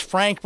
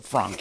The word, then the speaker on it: Franck: Actually, it's pronounced Frank, but we pronounce it Franck here because it's cooler.